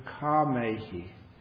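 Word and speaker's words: kāmehi: Kamehi, K long A M E H I. And the reason I have to spell that out is it's nothing to do with karma. It's nothing to do with the action and the law of karma.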